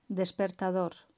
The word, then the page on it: despertador: Spain